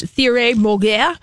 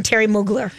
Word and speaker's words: Thierry Mugler: Thierry Mugler. – Okay. – Here's what you called him, and I called him Terry Mugler.